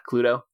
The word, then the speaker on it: Cluedo: Cluedo. – Am I saying that right? Yeah, Cluedo.